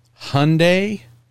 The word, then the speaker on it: Hyundai: Hyundai like Sunday. – Hyundai. – That is how we have been instructed by the factory.